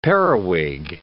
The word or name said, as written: periwig